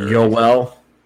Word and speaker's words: Joel: I like Joel Armia a lot. I just like him as a player. He's not like a fancy player. – Yoel. – Oh, is that how you say it? Sorry.